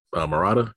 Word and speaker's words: Murata: Yusuke Maruta? That's how you say it? – Murata. – Yeah, Murata, my bad.